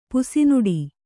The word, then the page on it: ಪುಸಿನುಡಿ: pusi nuḍi